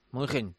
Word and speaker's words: München: Los alemanes no dicen Múnich, dicen München. O München. O como... – München.